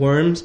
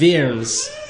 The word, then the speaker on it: Worms: We would read this, 1521, as a diet of worms, but it's pronounced a diet of worms.